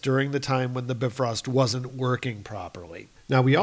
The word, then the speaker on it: Bifrost: We have the Bifrost, or sorry, we have to pronounce it properly because Robert is listening, Bifrost.